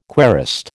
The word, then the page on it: querist: American English